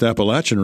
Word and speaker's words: Appalachian: I hope I pronounced Appalachian right. – Yes, you did, Larry, and it's nice to be here.